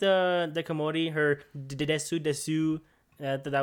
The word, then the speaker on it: desu: You know, when when you're a kid, you say desu like that. You know, because you pronounce the u at the end of des,